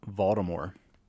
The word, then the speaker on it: Voldemort: But J.K. Rowling said the T is silent. So it is Voldemort.